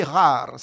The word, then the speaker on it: ghar: Alors, il faut prononcer rare, ça s'écrit GH en caractère latin, hein. GHAR. Le GH, c'est pour tous les gens qui ont fait un peu, ne serait-ce qu'un peu d'arabe, on retrouve de suite le son guttural rain, c'est pas impossible du tout.